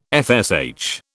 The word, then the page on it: FSH: British English